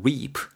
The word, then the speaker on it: reap: r e a f 발음 reap reap 이렇게 길게 발음하실 길게 발음하셔야 되고요.